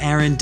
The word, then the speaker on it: Aaron: And I'm on Twitter at Aaron Dinin.